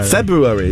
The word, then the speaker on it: February: How do you pronounce February? – February. – Yeah, no, there's an R. – February is the correct pronunciation.